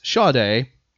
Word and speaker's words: Sade: You're going to mispronounce the Sade. – Name. What were you going to say? – Sade. – It's Sade. That is the correct.